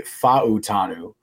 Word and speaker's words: Fautanu: I learned today that I believe it's his name is actually pronounced Troy Fautanu, Fautanu or something Fautanu? – Like that. Fautanu, at least according to Dane Brugler's pronunciation guide. I don't know. It says Fautanu.